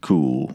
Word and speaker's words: Kuhl: So how do you say it? I've never known. I've never asked him. – Like Kuhl. – Yeah. – Like Kuhl Beans. – Mm-hmm. Kuhl Beans.